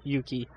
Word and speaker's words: Yuki: Valkyrie Yucky. – I thought it was Yuki. – I'm not sure how to pronounce it. – I'm pretty sure it's Yuki, because Yucky sounds bad.